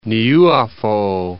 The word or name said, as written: Niuafo'ou